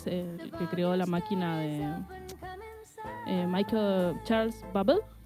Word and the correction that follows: Babbage: ¿Babel? ¿La máquina de Babel? – Sí, Charles Babbage. – Babbage. Ok. Babbage. No sé cómo se pronuncia en realidad en ese idioma.